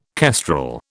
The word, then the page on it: kestrel: British English